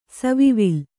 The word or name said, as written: ಸವಿವಿಲ್ಲ